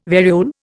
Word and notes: variole: Indian

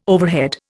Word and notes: overhead: Indian

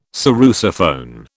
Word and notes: sarrusophone: British English

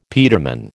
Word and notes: peterman: American English

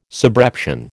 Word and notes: subreption: American English

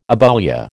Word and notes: aboulia: American English

